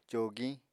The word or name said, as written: cogiñ